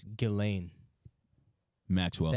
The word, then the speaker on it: Ghislaine: It's Ghislaine. – Maxwell. – The S, I guarantee that S is silent.